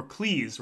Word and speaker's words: Cleese: Right, or Cleese, rather, as he will remind you, it rhymes with cheese.